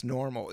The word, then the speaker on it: NORML: If you look at the old Cheech and Chong movies, Cheech always had this badass Normal. It's like purple and it has Ormal, or and it's spelled N-O-R-M-L.